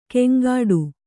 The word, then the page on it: ಕೆಂಗಾಡು: keŋgāḍu